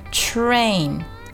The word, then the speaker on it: train: 接下来我们来看 train 这个单词，这个单词很容易，很多同学就会把它读成 train train 啊，注意一定要把卷舌音 r 的音给发出来啊，舌尖往后卷，舌尖呢不要接触到口腔里的任何一个部位，悬空往后卷一点，然后呢去发一个 r 的音 train train train 啊，不要读成 train train 啊。再